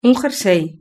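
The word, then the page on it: un jersey: Spanish (Spain)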